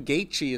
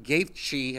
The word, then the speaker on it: Gaethje: But um so Justin Gaethje has the hardest name to fucking pronounce. It's G A E T H J E. Gaethje. – I don't Not Gaethje.